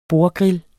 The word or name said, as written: bordgrill